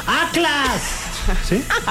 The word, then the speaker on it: Atlas: No sé si lo pronuncié bien, pero ¡Atlas! ¿Sí? – Así, tal cual. – Sí, ok. – Sí. Te sale perfecto.